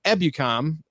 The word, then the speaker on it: Ebukam: Defense, Michael Brockers at defensive end, Aaron Donald and A'shaun Robinson at defensive tackle, Leonard Floyd, Traven Howard, and Samson Ebukam. Ebukam? – You said it right the first time.